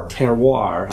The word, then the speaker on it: terroir: They refer to something, and how do you pronounce it? – Terroir. – Terrier.